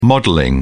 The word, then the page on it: modelling: British English